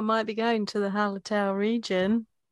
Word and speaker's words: Hallertau: Goldings and Helleratu. I can't pronounce that second half. – Hallertau, I cannot pronounce that. I can never pronounce that. That is a fucking hard one. – Hallertau, Mittelfrüh.